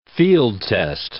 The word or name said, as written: field-test